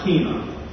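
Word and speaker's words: hina (ἵνα): There is an important word in John 3:16, a conjunction pronounced hina, and it is generally translated into the English by the word that.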